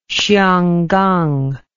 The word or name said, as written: xianggang